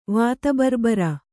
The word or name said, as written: ವಾತಬರ್ಬರ